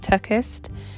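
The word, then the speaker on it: TerKeurst: And I'm also currently reading a book by Lisa, I believe her surname is pronounced Turkist, and it's called Forgiving What You Can't Forget.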